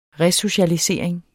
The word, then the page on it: resocialisering: ˈʁε-